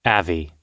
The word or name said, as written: avi